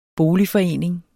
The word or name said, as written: boligforening